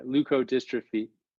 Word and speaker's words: leukodystrophy: Yeah, leukodystrophy, 4-H leukodystrophy.